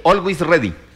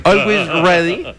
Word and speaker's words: Always Ready: Al-Wais. – ¿De ¿Sí? – quién? – ¿Se dice así? – Always Ready. – ¿Cómo? – Always Ready. – Always Ready.